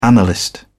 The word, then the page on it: analyst: British English